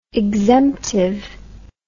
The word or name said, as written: exemptive